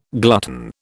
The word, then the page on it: glutton: British English